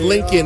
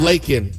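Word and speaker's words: Laken: It sounded like he said Lincoln instead of Lakin.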